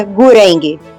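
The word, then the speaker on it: Gurenge: E o nome da música é Gureng. Gu-R-E-N-G-E. Gureng.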